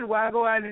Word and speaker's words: Wahgwaan: Wahgwaan Entertainment. Did I say it right? – Well, you did good. Wahgwaan Entertainment.